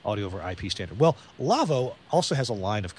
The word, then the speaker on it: Lawo: Our show is brought to you by the folks at Lavo, L-A-W-O, console company, pronounced Lavo, but spelled L-A-W-O.